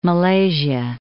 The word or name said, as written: Malaysia